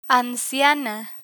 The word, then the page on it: anciana: spanish